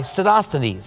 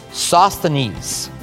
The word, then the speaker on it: Sosthenes: So Crispus, who's a believer and believed, most likely is replaced by Sosthenes. Sosthenes. I was fine in my study today saying his name over and over again. When I get in front of you guys, I can't say his name. Sosthenes.